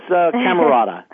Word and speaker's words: Camarota: Did I pronounce that correctly? Camarota? – That's close. Camarota.